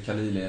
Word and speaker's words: Khalili: Är det Khalili man säger? – Eller? – Jo, det ja, brukar vara sådana uttal på KHA. Men man säger ju Khalili, eller som Johnny, Chalalala.